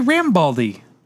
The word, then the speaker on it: Rambaldi: Rambaldi. – And then he did King Kong, the 1970 version of King Kong. – Why didn't you say Rambaldi? – Yeah. So I mispronounced his name.